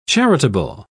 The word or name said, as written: charitable